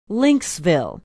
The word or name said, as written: lynxville